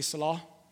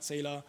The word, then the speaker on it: Selah: The, the, proper way of saying the Hebrew word is like sila or sila, but we've learned to say salah. Um, some people say Selah, some people name their daughter Selah, and it's wrong pronunciation, it's not Selah.